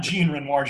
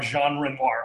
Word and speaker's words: Jean Renoir: The closest it'll get is Jean Renoir. We will pronounce it not Jean Renoir, Jean Renoir. I think that's the closest.